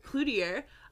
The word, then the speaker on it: Cloutier: Cloutier.